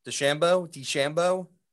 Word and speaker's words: DeChambeau: Bryson DeChambeau's, how do you say it? DeChambeau. – Bryson DeChambeau. – It makes it sound like you're going to do like Rochambeau.